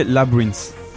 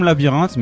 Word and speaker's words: Labrinth: mais c'est Labrinth. Ça s'écrit comme labyrinthe, mais il n'y a pas le i avant. Bref, labyrinth, je pense que disent comme ça en anglais.